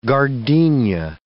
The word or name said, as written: gardenia